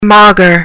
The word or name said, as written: maugre